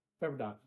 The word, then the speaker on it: Pepperdine: Pepperdine.